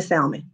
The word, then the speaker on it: salmon: You are, and I know the L is silent. I understand that, but I was raised on Plymouth, Wyoming, in the heart of the ghetto on the west side of Detroit. Everybody around me called it salmon.